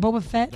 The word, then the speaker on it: Boba Fett: How do you say that, Boba Fett? – That's actually correct.